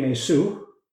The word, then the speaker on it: Yesu: For example, in Greek, there's no sh sound. And so Yeshua became Yesu.